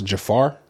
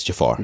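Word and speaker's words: Jaafar: I think his name is pronounced Jafar. – Yeah, it's Jafar.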